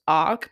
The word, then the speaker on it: Aki: I think it's pronounced Ark, but it's spelled A-K-I.